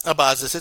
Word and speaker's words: Abazis: I hope I say his last name right. I think I've been saying it wrong for like 3 years, but Abazis, it's okay.